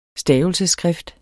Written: stavelsesskrift

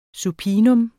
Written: supinum

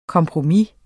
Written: kompromis

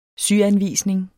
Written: syanvisning

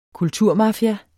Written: kulturmafia

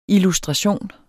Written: illustration

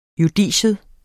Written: JUDICIETS

